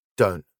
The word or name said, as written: dont